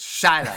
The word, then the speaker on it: Shiloh: Whatever you say, Guy Blow, it's Shiloh. You said it wrong, stupid.